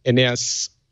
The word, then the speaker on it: Aeneas: Oh, he's a little boy called Aeneas. – Oh, Aeneas. – Aeneas, yes. – Aeneas. – From, yeah. – Like Aeneas Nin? Aeneas? – Aeneas.